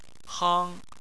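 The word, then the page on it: hang: tone 1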